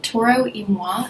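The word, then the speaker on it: Toro y Moi: Toro Imoi, I think is how you pronounce it. – Do you know how to spell it? – It's T-O-R-O. – T-O-R-O. – And then space M-Y, and M-O-I, like a separate word. – Y-M-O-I? – Y and then space M-O-I.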